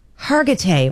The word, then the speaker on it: Hargitay: Now, Mariska Hargitay. How do you say her name? – Hargitay.